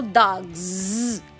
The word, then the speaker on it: dogs: que é o vozeamento do S em final de palavra, como por exemplo dogs. É com z no inglês.